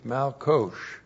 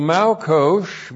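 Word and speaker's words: malkosh (מלקוש): M-A-L-Q long O-S-H, malkosh. So malkosh means the latter rains.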